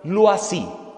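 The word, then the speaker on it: Loisy: Su apellido L-O-I latina, S-I griega. Se pronuncia Luasi. L-O-I-S-I griega.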